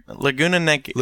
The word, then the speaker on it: Laguna Niguel: You're Laguna Niguel? – Laguna Niguel.